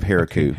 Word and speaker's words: Heroku: Yeah, I think I think you're thinking of Heroku. Okay. Heroku. – Oh, you got to okay. put the emphasis right. on the right syllable. Oh, Her- Heroku.